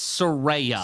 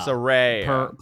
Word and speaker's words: Saraya: And is it Saraya or Saraya? – It's Saraya. – Saraya. – Per her Twitter. – Yes. – She explained how to pronounce it.